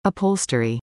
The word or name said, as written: upholstery